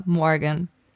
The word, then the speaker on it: Morgan: Also tatsächlich, von mir aus könnte es auch Deutsch aussprechen, aber richtig ist es Morgan. – Maya Morgan. – Mit diesem super amerikanischen Morgan.